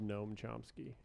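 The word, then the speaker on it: Gnome Chomsky: It's called Noam Chomsky. But it's spelled like gnome, like garden gnome.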